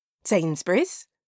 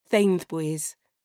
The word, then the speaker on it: Sainsbury's: Sainsbury's? Sophia says now, looking back at the shops, now that we are safely past the birds. She still pronounces it Thainthbuys. So cute it makes my heart squeeze.